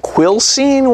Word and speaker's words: Quilcene: And Mike in, is it Quilcene, Washington? Am I saying that right, Mike? – You are.